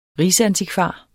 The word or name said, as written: rigsantikvar